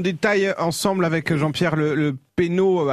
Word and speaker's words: Pesnot: P-E-S-N-O-T, que vous prononcez certainement Penaud également.